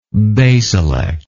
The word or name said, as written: basilectal